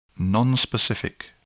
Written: nonspecific

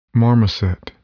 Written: marmoset

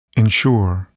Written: ensure

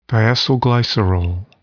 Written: diacylglycerol

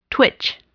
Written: twitch

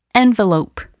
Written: envelop

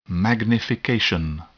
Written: magnification

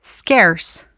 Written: scarce

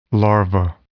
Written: larva

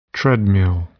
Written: treadmill

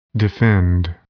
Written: defend

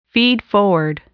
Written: feedforward

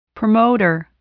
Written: promoter

